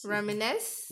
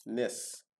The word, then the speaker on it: reminisce: Reminisce? I can never say that word. – Yeah. – It's the word of the day. I can't pronounce. – Reminisce. – Reminisce? – Yeah. – Nens? – Nis. – Nis.